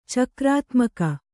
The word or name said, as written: ಚಕ್ರಾತ್ಮಕ